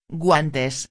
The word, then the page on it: guantes: espanhol